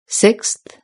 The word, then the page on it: sixth: British English